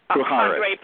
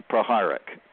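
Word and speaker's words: Puharich: And they go through all of the different civilizations, and then at a certain point, I know I'm going to say his name wrong, but it's like Andreas Pradish or Puharic. – Thank you very much. That's it. Proharic.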